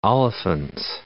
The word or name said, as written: Olifants